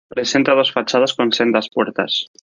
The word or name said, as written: fachadas